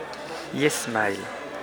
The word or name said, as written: yesmail